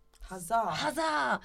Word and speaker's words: Huzzah: How would you, and our brand, by the way, is called Huzzah. – Huzzah. – How do you spell it? Huzzah. H-U-Z-Z-A-H.